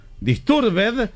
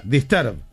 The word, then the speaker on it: Disturbed: No hay ninguna palabra en inglés que al final se pronuncie ed. Disturbed se pronuncia disturb.